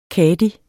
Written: kadi

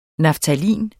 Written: naftalin

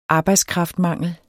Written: arbejdskraftmangel

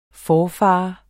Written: forfader

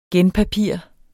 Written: genpapir

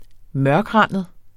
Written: mørkrandet

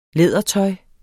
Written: lædertøj

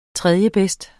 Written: tredjebedst

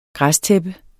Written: græstæppe

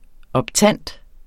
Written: optant

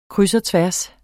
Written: kryds

